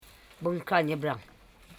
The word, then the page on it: bunkranyibla: bunkra.nyi.bla